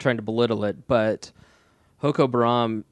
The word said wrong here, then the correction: Boko Haram: am I saying that right? – Boko Haram, I'm sorry.